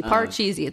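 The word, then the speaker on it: Patxi's: there's a place in the Bay Area called Pachis. Everybody pronounces it differently. – Parcheesi. It's called Parcheesi. – It's spelled P-A-T-X-I apostrophe S. So you can understand the mispronunciation.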